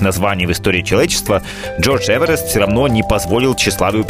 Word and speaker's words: Эверест: Еще интересно, что его фамилия произносилась немного иначе. Это было что-то вроде Эверест.